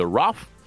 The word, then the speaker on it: rough: А иногда в конце слова оно произносится как Ф, как, например, в слове rough, грубо, или enough, достаточно.